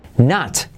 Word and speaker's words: knot: Knot, que es un nudo. Knot. Eh, la K no se dice y la O se hace como una vaya lío, Nat.